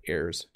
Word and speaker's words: heir: So they are both heirs to the throne. So I thought I would throw that one in there because heir is pronounced the same way as air. Okay, they're the same pronunciation.